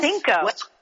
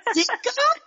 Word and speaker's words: Cinco: Sino? Did I say that right? – Cinco. – Cinco? Cinco. – Yes.